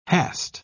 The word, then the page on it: hast: British English